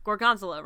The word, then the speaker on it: Gorgonzola: Gorgonzola rhymes with gondola.